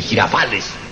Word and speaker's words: Girafales: Y finalmente, el maestro, don Rubén Aguirre Girafúchila. – ¡Ta, ta, ta, ta! No se dice maestro, se dice maestro, Rubén Aguirre y Girafales.